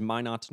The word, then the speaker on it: Minot: Am I pronouncing this right? Minot? – Yes, it is Minot, North Dakota.